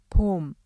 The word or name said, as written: 봄